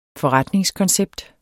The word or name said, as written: FORRETNINGSKONCEPT